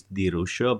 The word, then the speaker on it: Di Ruscio: Alessandro is pronounced perfectly, and the surname is Di Ruscio, but I don't expect you to achieve this level of Italian pronunciation.